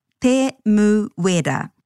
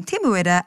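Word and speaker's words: Temuera: He may actually be often known as Tim, but to pronounce his full name, Te Muera, again, stay true to the vowel sounds, a, e, I, o, u, and break the name into syllables, which will always end with a vowel.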